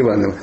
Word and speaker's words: Иванов: А есть люди, у которых, ну, как Кугель про чеховского Иванова. Почему ударение на Иванов? Ну, если Иванов – это русский Гамлет. А если Иванов – это миллион первый.